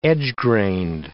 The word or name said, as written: edge-grained